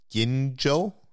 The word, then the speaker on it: Gingell: It's a new design created by graphic designer Barry Gingell, I think is how you pronounce the name, G-I-N-G-E-L-L,